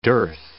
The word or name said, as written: dearth